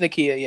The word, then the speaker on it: Nakia: Sorry if I say it wrong, but Nakia, correct? Or Nakia? – It's Nakia, yeah. – Nakia.